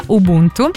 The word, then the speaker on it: Ubuntu: Si pronuncia Ubuntu,